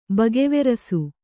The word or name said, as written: ಬಗೆವೆರಸು